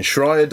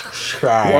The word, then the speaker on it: Schreier: There's an S and a Sh, and I can't do both. – Yes, Schreier. – One or the other, so it's Jason Schreier.